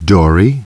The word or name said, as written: Dory